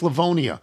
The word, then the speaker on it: Livonia: Pronounced Livonia.